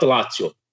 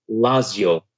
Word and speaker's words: Lazio: Not Lazio. Lazio. From now on, I'm going to say it like that.